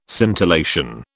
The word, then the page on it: scintillation: British English